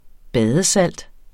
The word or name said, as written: badesalt